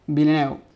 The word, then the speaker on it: Villeneuve: Pero Villeneuve.